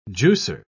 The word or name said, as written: juicer